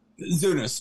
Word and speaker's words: Zunes: Zunes, two syllables.